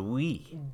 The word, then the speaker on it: Wii: W-I-I. – And a lot of people don't know it's pronounced Wii. – Wii. – Wii. – Wii. – Like, you know, as if you were saying yes in French.